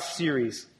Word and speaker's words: Ceres: Demeter or Demeter, the Greek goddess Demeter, her Roman equivalent name is Ceres. Okay, and I didn't say Siri, like, hey Siri, tell me a joke. On your iPhone, okay? We're talking about Ceres.